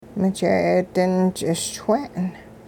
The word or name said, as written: mije'eedin-ch'iswe'en